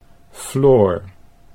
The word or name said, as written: Floor